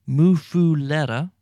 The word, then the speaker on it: Muffuletta: It's called the Muffuletta. The Muffuletta, and it's spelled M-U-F-F-U-L-E-T-T-A, Muffuletta. And sometimes you'll find somebody calling it a Muffuletta, but most people call it a Muffuletta.